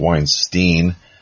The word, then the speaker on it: Weinstein: the whole Weinstein, or if some people might pronounce it Weinstein,